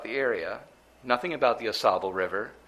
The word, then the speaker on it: Ausable: The title for this split sermon is Lessons from the Ausable. And that's Lessons from the Ausable. And to spell that, because it is somewhat of a strange spelling, it's A-U space S-A-B-L-E, Ausable.